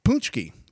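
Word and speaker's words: pączki: I actually was talking with some people last night on Twitter about the correct pronunciation of Poonchki, which is spelled P-A-C-Z-K-I, but somehow we get Poonchki.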